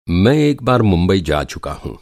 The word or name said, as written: मुंबई